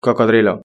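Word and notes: cocodrilo: Spanish (Spain)

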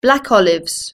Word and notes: black olives: British English

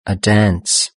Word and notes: a dance: British English

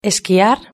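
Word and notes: esquiar: Spanish (Spain)

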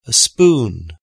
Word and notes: a spoon: British English

